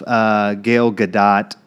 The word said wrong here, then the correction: Gal Gadot: I think it might be Gal Gadot. – Gal Gadot. That would make more sense.